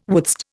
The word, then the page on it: wouldst: Indian